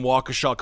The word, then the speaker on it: Waukesha: Marcus, I do want to thank you for pronouncing Waukesha correctly.